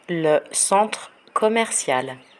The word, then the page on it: le centre commercial: french